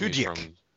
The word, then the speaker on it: Tudyk: It's directed by Eli Craig, and it stars Tyler Labine and Alan Tudyk, I think is how you pronounce his name. – Tudyk.